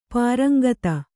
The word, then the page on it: ಪರೇಂಗಿತ: parēŋgita